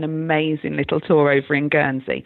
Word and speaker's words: Guernsey: Steve asks, we are visiting Guernsey for, did I say that right, Guernsey? – Mm-hmm.